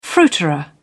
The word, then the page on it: fruiterer: British English